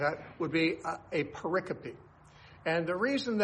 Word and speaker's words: pericope: It's spelled P-E-R-I-C-O-P-E. You would think it's pronounced pericope. But actually, the correct pronunciation is pericope.